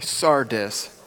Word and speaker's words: Sardis: So today we are on Sardis. Say that with me, Sardis. All right. Just say it with confidence. That's how you say it.